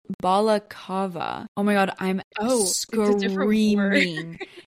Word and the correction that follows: balaclava: It's balakava. Balaclava. Balaclava. This word doesn't sound like a real word anymore. I'm screaming. Balaclava. That's how you say it.